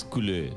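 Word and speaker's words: Coulais: Este es Colleil. – Bruno Colleil. – Como si fuera del Barça. – Es culé, culé.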